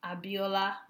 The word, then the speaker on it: Abiola: Facebook is at Abiola